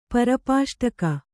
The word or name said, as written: ಪರ್ಪಾಷ್ಟಕ